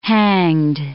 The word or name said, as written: hanged